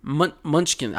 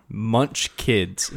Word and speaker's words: munchkins: The three multi-covered munchkins. I think you said it right. It's lunch and then kids. Kins. Good. Wow. – Munch kids. No, munchkins.